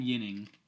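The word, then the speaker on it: Ginning: So we picked Adam Yining, soft J, or soft G, I'm sorry. You don't often see a soft G.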